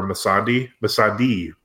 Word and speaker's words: Missandei: Oh, no, we would be disrespectful if we didn't mention that Grey Worm and all those people went to where Missandei, correct pronunciation, I might add, mm-hmm.